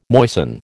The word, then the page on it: moisten: American English